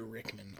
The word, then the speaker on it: Rickman: Peter Rickman, R-I-C-K-M-A-N.